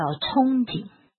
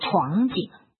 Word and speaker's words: 憧憬: 那有时候长官演讲他会讲错白字，比方说有一个字哦，这个很多人都不容易把它念对，那个叫憧憬啊、哦，对什么什么有憧憬啊、哦，那个读作憧人心。竖心旁旁边一个童，儿童的童，那个念憧憬。可是这个长官演讲的时候他念闯景哦，这个闯景，但是没有人敢纠正他哦。